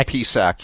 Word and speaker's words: Psaki: Jen Psaki. – Psaki, like what you get at like the Japanese rice wine. – Yes, exactly. Psaki. – Jean Psaki. – How about Winnie Psaki? – Yeah.